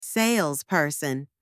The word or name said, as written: salesperson